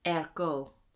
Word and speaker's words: ergot: ergot. I think it's French, so that's how I'm pronouncing it. – Okay. – But it's spelled E-R-G-O-T.